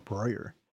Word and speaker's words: Breuer: But our friend, David, I always mess up the last name, David Boyer. – Yeah, it's spelled like that beer place that your friend has in Berlin, right? Maybe that's a correct pronunciation, Breuer.